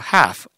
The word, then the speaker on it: half: half. A, H-A-L-F. half. L이 발음 안 되죠?